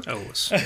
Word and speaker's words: Elwes: I didn't hear you say Cary Elwes. He said it's pronounced like Elvis with a W. – Elwes.